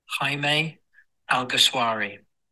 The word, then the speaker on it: Jaime Alguersuari: Jaime Alguersuari. So it's Jaime, like, hi mate. And then Alguersuari, like a sh on Alguersuari, or shwari, like a shawarma kebab.